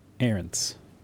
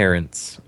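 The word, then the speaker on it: Ahrendts: Ahrens. – Ahrens. – Okay. Ahrens.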